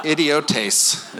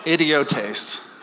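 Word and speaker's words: Idiotes (ἰδιώτης): Idiotes is how you say it. Idiotes.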